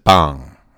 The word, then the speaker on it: Bang: We start off the show with a song called, it's spelled Bang, but they pronounce it Bong, from the group The Molinas.